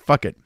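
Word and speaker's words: Phuket: I want to say fuck it, but it's not fuck it. It's a vegetarian festival. And you'll appreciate this. The fuck it or the puck it? Fuck it. Oh, it's fuck it.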